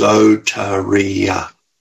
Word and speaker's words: soteria: Okay, salvation is the word soteria. Okay, pronounced soteria.